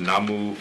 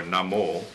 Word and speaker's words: Namo: There's also a point about whether this is, should be pronounced Namu or Namo. You see it both ways. Generally in Shinran's work, when you look at the furigana, the way in which he gives us pronunciation, generally Shinran presents it as Namo.